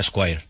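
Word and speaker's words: Esquire: Es un nombre muy difícil, se pronuncia Squire.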